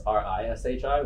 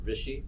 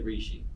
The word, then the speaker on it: Rishi: Like my real name is Rishi, which is Rishi, which is like the real way you'd pronounce it. But then growing up here, people say Reeshi.